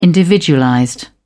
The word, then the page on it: individualized: British English